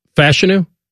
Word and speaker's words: Fashanu: Olumuyiwa Fashanu. – Okay. – Or wait a minute. It's Fashanu? – Fashanu, I believe, is what we're going with.